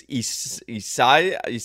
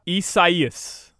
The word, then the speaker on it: Isaias: What's the pronunciation? It's Isasa? – Isaias.